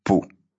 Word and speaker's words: пу: Само это слово, кстати, произносится почти так же, как и «пух», но без такого хрипения в конце. Это похоже на звук, который мы издаем, когда в жаркий летний день сдуваем мошку, прицепившуюся к рукаву.